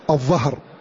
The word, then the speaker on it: الظهر: كذلك من هذه الألفاظ التي نبه الناظم عليها رحمه الله تعالى لفظ الظهر, لفظ الظهر وهو خلاف البطن, بطن وظهر, فالظهر بالظاء ولا ينطق بالضاد, والعوام ينطق بالظهر, العوام ينطق بالضاد, طبعا هذا خطأ, عند كتاب الله جل وعلا ينطق ويتلقى كما نزل.